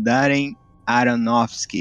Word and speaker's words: Darren Aronofsky: O novo filme aí desse ano do Darren Aronofsky.